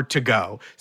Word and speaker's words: Togot: Our game today is called Waiting For To Go, spelled T-O-G-O-T, like Godot. Because brilliant. – If you spell it T-O-G-O, it's just waiting for Togo.